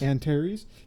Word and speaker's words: Antares: Is it Antares? – Yes. – Antares, because it says Andy plus Terry, with Andy Hildebrand.